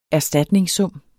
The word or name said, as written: erstatningssum